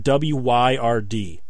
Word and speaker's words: Wyrd: And it's spelled Weird W Y R D. Wyrd the Reluctant Warrior.